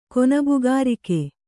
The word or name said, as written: ಕೊನಬುಗಾರಿಕೆ